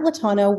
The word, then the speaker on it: Latana: So talk about Lantana, if I said the name correctly, and kind of how you guys are helping brands and what you guys do. – Of course. So, you know, at Latana, we are a B2B SaaS company.